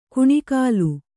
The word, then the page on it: ಕುಣಿಕಾಲು: kuṇikālu